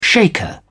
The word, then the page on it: shaker: British English